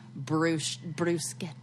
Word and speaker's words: bruschetta: And what is the right way? – Bruschetta.